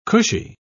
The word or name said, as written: cushy